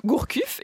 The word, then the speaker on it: Gourcuff: Sinon, on peut noter que Gourcuff est encore blessé.